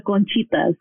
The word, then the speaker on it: conchitas: The podcast touches on all subjects, provides advice, and offers some laughs and tears for all the conchinitas out there. Did I say that right, conchinitas? – It's conchitas. – Conchitas. Okay.